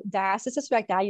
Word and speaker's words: diastasis recti: So diastasis recti, you said it right.